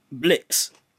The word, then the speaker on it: Blixkz: You may have heard of him actually, Blix. So it's spelt with an X. So it's spelt BLIXKZ.